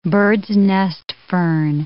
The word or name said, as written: bird's-nest fern